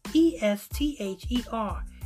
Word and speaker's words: Esther: Third, because I guess I guess the H must be silent because E S T H E R. S third. Third. A TH makes the third sound. Th, th sound. But, but I guess the H is silent.